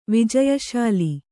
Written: ವಿಜಯಶಾಲಿ

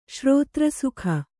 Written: ಶ್ರೋತ್ರಸುಖ

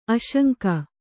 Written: ಆಸಂಗ

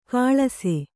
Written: ಕಳಸ

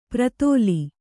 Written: ಪ್ರತಳ

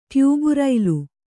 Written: ಟ್ಯೂಬುರೈಲು

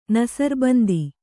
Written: ನಸರ್ಬಂದಿ

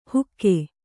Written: ಹುಕ್ಕೆ

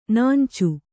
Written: ನೆಂಚು

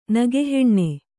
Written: ನೆಗೆಹೆಣ್ಣು

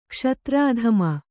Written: ಕ್ಷತ್ರಧಾಮ

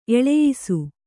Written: ಎಳೆಯಿಸು

